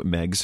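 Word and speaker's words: Meigs: they talk about that it's pronounced Megs because the guy's last name was Megs and it rhymes with eggs.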